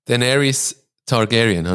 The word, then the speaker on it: Daenerys Targaryen: Daenerys Targaryen, habe ich es richtig ausgesprochen? – Ja.